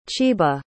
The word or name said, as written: chiba